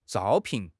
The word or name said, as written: zhao1 pin4